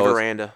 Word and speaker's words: Aranda: Or Arenada from Dave Aranda.